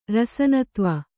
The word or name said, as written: ರಸನತ್ವ